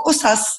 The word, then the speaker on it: Ossass: So Ossass literally means stories. – Right. And in modern standard Arabic, it'd be pronounced Qusas. – Qusas, exactly. Right.